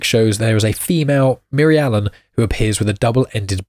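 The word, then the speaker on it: Mirialan: Now I will clarify, I find it very hard to pronounce this, so it's M-I-R-I-A-L-A-N. I think most people find it quite easy to pronounce, but clearly it's just me. Miri Allen.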